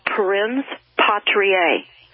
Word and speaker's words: Parens Patriae: I want to say this word slowly, and I'm going to spell it. It's called Parens Patriae, and it is spelled P-A-R-E-N-S, second word, Patriae, P-A-T-R-I-A-E.